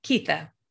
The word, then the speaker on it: Keitha: Keitha. – Keitha. Okay. – Yes. Like Keith with an A.